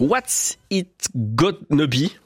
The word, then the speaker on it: What's It Gonna Be: Dusty Springfield avec le titre What's It Gonna Be, voilà, comme ça que ça se prononce, sorti en 1967, et Stéphanie Ivanovitch à la programmation musicale nous indique que c'est un morceau moins connu de Dusty Springfield.